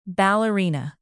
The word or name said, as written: ballerina